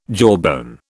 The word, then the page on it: jawbone: British English